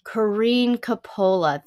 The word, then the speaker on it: Corinne Coppola: Corinne Coppola.